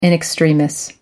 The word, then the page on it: in extremis: American English